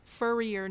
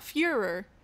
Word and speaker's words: Furrier: Furrier Number One. Not Fuhrer. It was spelled like Furrier. Like, that dog is furrier than that dog.